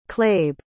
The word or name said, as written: clabe